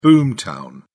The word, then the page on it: boom town: British English